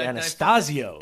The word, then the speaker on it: Anastasio: This is Trey Antizito. – Nah, that'd be Anastasio. – Anastasio, yeah, I said it right before.